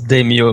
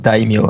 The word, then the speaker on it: daimyo: Oui, souvent les élèves qui arrivent à mon cours en première année, ils prononcent ça avec les phonèmes du français, donc ils prononcent daimyo, mais ça prononce pas daimyo, c'est daimyo, hein, parce qu'il y a un AI, hein.